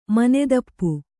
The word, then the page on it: ಮನೆದಪ್ಪು: manedappu; Kannada